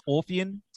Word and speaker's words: Orphean: Is it pronounced Orphean Testament? – Yes.